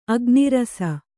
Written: ಅಗ್ನಿರಸ

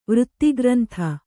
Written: ವೃತ್ತಿಗ್ರಂಥ

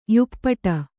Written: ಯುಪ್ಪಟ

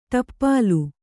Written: ಟೋಪಲಿ

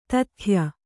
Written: ದೂತ್ಯ

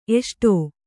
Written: ಎಸಡು

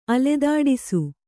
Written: ಅಲೆದಾಡಿಸು

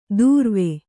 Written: ದೂರ್ವೆ

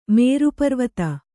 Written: ಮೇರುಪರ್ವತ

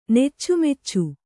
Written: ನೆಚ್ಚುಮೆಚ್ಚು

